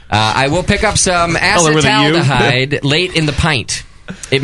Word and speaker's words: acetaldehyde: Because it's not acetaldehyde. I'm trying to pronounce it right. It's acetaldehyde.